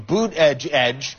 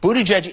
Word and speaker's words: Buttigieg: Buttigieg is a Maltese name. It means owner of chickens. – All right, so you pronounce it Boot, B-O-O-T, Edge Edge.